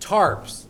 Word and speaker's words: tarps: And I would love going to his house because the whole front yard was full of blue tops. Am I saying that correctly? Tops. – Blue tops. – You know, the blue plastic tops. And that. – Tarps. – Tarps. – Tarp. – Okay, tarp. Tarp.